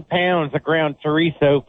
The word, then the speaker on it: chorizo: I've done spaghetti, and I think it tastes better with the chorizo than I do with the ground beef.